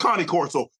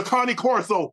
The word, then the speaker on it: Cane Corso: Let's talk about the Kangol. – What? – Where did it originate from? The Kangol. – The Cane Corso? – It's pronounced Cane Corso, but okay, go ahead, take off.